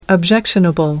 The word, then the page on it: objectionable: American English